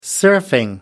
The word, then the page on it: surfing: American English